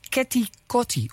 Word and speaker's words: Keti Koti: En volgens mij moet officieel zelfs, ik heb een kenner hier vlak bij mij, als Keti Koti uitspreken. Dus echt als Keti Koti.